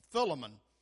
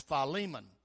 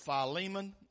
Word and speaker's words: Philemon: And I'm for years and years, I pronounced it Philemon, but then when I went to Bible college, I found out that's not how you pronounce it. It's Philemon. It's the book of Philemon, and it's right after Titus.